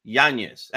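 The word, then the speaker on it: Yáñez: That's why it's Yanez and not Yanez. Yanez. And it should have an accent on the A, too, by the way. Okay. Yanez. – Well, let's so it's like N-I, it's almost like N-I-E, Yanez, that sort of thing. That's the way you pronounce it.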